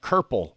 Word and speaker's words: kerple: Do you want to know what the 2 archaic words are that rhyme with purple? – But of course. – One of them is herple. It's a British word which means to walk lamely or hobble. And then also we've got kerple. That's C-U-R-P-L-E, which is a Scottish word.